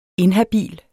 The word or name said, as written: INHABILT